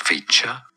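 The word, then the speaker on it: Khvicha: It sounds like Hvicha, Hvicha, Kvaratshelia, Kvaratshelia.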